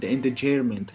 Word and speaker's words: endangerment: Uh, menacing illegal discharge of a firearm prohibited use of a weapon and reckless endanger- and endangerment, che non so neanche come si pronuncia.